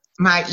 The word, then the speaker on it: Miasha: Miasha.